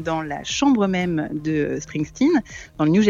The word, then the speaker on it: Springsteen: Alors, le second film, redevenons sérieux un instant, avec Springsteen. C'est bien prononcé, non ? – Tout à fait, je ne l'aurais pas mieux fait, donc je vous laisse la prononciation.